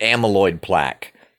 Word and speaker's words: amyloid plaque: Let me check that real quick. Yes, that is how you say it, amyloid plaque.